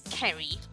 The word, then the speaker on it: Ceri: But lots of people, when they see it written down, because it's written C-E-R-I, they don't really know how to pronounce it. So I get all kinds of different variations. I get Ceri, Ceri, Sherry.